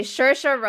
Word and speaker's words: Saoirse: Okay, Saoirse Ronan.